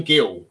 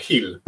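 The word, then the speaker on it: Gil: Rockstar, Brian Gill, or Hill, I assume you pronounce it. – Who's apparently a very good player. But the thing that makes me laugh is, because obviously we're English, most people are just going to say Gill, it instantly makes me think of the character Gill, who's constantly losing his job or worrying about money in The Simpsons.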